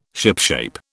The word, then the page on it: shipshape: British English